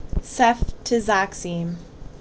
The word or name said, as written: Ceftizoxime